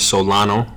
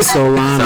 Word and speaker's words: Solano: Yeah, the name is Solano. We just reviewed that. – Yeah. – We did. – Anthony Solano. – Solano. – Said it how it's spelled.